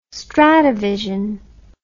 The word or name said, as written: Stratovision